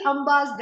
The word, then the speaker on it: Amba: Trini Ambakani Asya, we can also say that these Amba, the three Ambas that he has are Amba with a long A, which means mother.